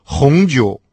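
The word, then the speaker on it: хунджу (红酒): А вот французы, например, вообще не представляют себе обед без бутылки красного вина «хунджу». «Хун» – прилагательное «красный», произносится со вторым тоном, а «джоу» – третий тон – это вино.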